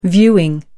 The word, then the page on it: viewing: American English